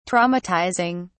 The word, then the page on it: traumatizing: British English